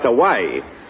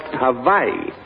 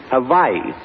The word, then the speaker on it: Hawaii: It's not pronounced Hawaii. It's pronounced Hawaii. On the islands, the W is pronounced like a V. Hawaii.